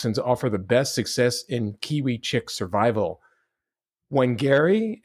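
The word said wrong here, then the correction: Whangārei: Yeah, in Te Reo Māori, in the Māori language, the WH sound is pronounced fa.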